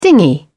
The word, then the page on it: dinghy: British English